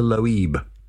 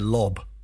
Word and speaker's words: Loeb: It's spelled L-O-E-B. – Yeah. – To me, that's Loeb, but it could have been Loeb. – I'd have said Lob. – You'd have said Lob.